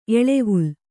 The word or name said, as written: ಎಳೆವಲೆ